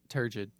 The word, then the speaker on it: turgid: how do you say this word? – Oh, turgid. – Turgid, yeah.